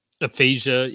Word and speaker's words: aphasia: Asphasia. – Aphasia, yeah.